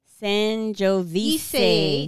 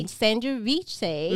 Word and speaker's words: Sangiovese: Did I say it right? – Yeah. Sangiovese. – Sangiovese.